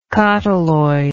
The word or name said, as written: cotyloid